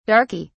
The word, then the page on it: darkey: British English